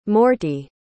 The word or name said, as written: morty